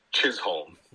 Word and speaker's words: Chisholm: And Jazz Jizzle. – Yeah. – Is that how you say his name? – His name is Chisholm. – With a ch. Like a ch. Yeah. C-H.